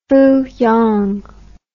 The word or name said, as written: fooyong